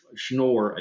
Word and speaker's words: Schnorr: A couple of months ago, another person, I don't know his nationality, Schnorr, I think is how you say his name, S-C-H-N-O-R-R, I think I'm saying that right.